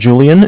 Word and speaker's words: Julian: My name is Julian. It's J-U-L-I-A-N. – Mm-hmm. – And I'm calling from Bank of Cardiff. It's spelled C-A-R-D as in dog, I-F-F as in Frank.